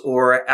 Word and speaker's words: Aage: We actually got a friend and fellow troth member from Denmark who recorded the way you pronounce A-A-G-E in Danish. And here he is. – The name is pronounced O.